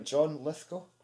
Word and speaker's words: John Lithgow: John Lithgow, is it? – Yes. – Yeah, got his name right. Yeah, yes. John Lithgow.